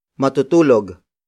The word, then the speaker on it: matutulog: Matutulog na siya.